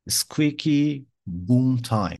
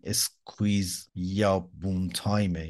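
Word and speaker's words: squeaky bum time: اسکویکی بوم تایم، بعد دو تا تلفظه، یه سری میگن این همون اسکویکی بوم تایمه، یه سری میگن این اسکویز یا بوم تایمه، یعنی با یک لحجه اسکاتلندی.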